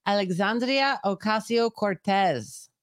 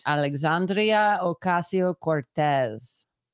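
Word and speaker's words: Alexandria Ocasio-Cortez: That would be Alexandria Ocasio Cortez. – That is all correct. – Alexandria Ocasio Cortez.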